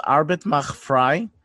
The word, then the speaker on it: Arbeit macht frei: If I'm not mistaken, it's pronounced Arbeit macht frei.